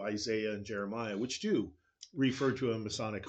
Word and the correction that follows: Messianic: hope. – Right. Right. – Messianic hope. – That's right. It's the morning for mispronunciations.